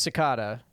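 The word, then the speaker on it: Secada: I believe it's Cicada. – Yeah, John Cicada. – It's definitely Cicada.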